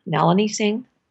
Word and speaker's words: Nalini Singh: I never know how to pronounce her name. Nalini Singh. – You got it right.